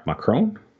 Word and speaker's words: Macron: And uh, there's a huge protest uh, protesting the current president, um, Emmanuel Macron.